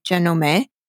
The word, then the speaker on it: Janome: Janome, or if you pronounce it the Japanese way, Janome, meaning the eye of the snake, was established in 1920 and named after the round bobbin technology that the company sells.